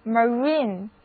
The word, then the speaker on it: marine: 英语中 MAR 这个词根就是表示海， INE 是一个形容词后缀，把词根和后缀连在一起得到的单词就是 marine， MARINE，字母 I 的读音是长的 E，第二个音节是重读音节， marine 意思就很好理解了，词根表示海，所以形容词 marine 的意思就是海的、海上的、航海的。